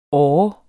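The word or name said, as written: ÅRE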